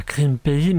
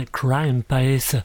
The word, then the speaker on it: Crime Pays: Alors, je ne sais pas dans quelle galaxie j'étais précisément, mais j'ai involontairement écorché le nom du titre du morceau que nous venons écouter. Ce n'est pas Crime Pays, mais Crime Pays.